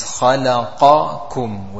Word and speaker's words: خَلَقَكُمْ: So the correct pronunciation is خَلَقَكُمْ with a qaf and then a kaf.